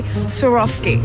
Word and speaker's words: Swarovski: How do you say Swarovski? – Swarovski.